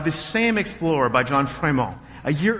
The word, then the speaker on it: Frémont: Frémont. I'm pronouncing it with a French accent.